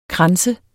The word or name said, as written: kranse